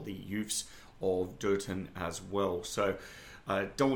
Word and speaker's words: Durton: So Dirt Town centers around the disappearance of one of the young girl citizens of Dirt Town, Durton, as I should pronounce it,